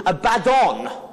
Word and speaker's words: Abaddon: I always get the emphasis on the wrong, get the emphasis on the wrong syllable. It's, um, it's Abaddon. I always say Abaddon, but it's Abaddon.